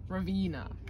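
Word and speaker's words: Raveena: Ravina.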